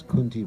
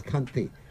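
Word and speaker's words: Kanthi: Remember when we first joined the movement, everyone would pronounce it as Kunti Mala. Yeah, it's Kanti, which means the neck.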